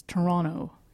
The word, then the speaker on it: Toronto: Yes, as Ben Affleck reminds us in Argo, the true Canadians do not pronounce the T. It's Toronto.